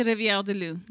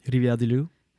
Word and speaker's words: Rivière-du-Loup: I feel like it's weird because you don't roll your R's in English. So it's like you're going through do-do-do-do talking da-da-da-da and Rivière du Loup. It just feels like...